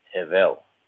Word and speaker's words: Hevel: In Hebrew, the name is actually pronounced as Hevel.